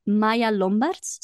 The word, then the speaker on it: Maya Lombarts: Both are Maya Lombarts, so I will spell that because it's not an English name.